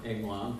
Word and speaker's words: angelon: is it's pronounced angelon, which is like angel.